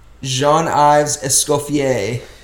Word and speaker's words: Jean-Yves Escoffier: Jean-Yves Escoffier.